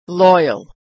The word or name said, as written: loyal